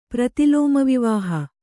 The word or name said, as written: ಪ್ರತಿಲೋಮವಿವಾಹ